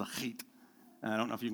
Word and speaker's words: chet: You have to suck it back in the back of your throat. It's spelled S uh, C H E T, but the CH is the sound from Bach, Hach, and it's uh, eighth, height, height, something like that.